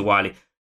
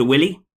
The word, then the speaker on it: Wily: Dr. Wily. For 37 years, I have been pronouncing that Dr. Willy.